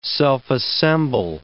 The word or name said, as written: self-assemble